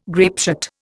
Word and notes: grapeshot: Indian

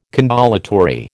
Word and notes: condolatory: American English

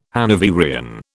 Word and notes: Hanoverian: British English

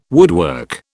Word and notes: woodwork: British English

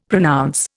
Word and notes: pronounce: Indian

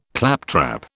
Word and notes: claptrap: British English

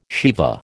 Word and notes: Shiva: American English

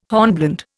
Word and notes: hornblende: Indian

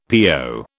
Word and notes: PO: British English

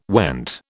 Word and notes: went: British English

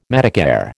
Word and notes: Medicare: American English

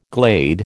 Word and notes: glade: American English

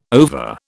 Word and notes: ova: British English

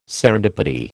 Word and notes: serendipity: American English

